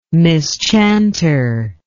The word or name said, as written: mischanter